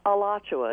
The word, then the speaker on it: Alachua: Alachua.